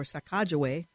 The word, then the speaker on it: Sacagawea: A remarkable expansion of cancel culture, a statue of Lewis and Clark and Sacagawea, or Sacagawea, is apparently the more accurate pronunciation, was toppled in Charlottesville.